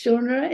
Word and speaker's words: genre: It's a novel about a coach, and it's in my favorite genre. How do you say that? – Yes, genre, indeed.